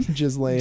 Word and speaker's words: Ghislaine: how do you say her fucking name? – Ghislaine.